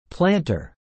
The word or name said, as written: planter